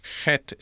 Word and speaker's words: Het: The Hebrew word for ruach ends in that Hebrew letter het, which most English speaking people find it so hard to pronounce. Het is a kind of continuous, outgoing guttural breath.